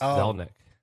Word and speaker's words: Zelnick: How do you pronounce his last name? – Zelnick, I think is right.